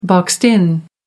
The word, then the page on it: boxed in: American English